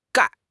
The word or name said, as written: 钙